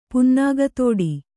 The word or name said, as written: ಪುನ್ನಾಗತೋಡಿ